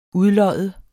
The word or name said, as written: udlodde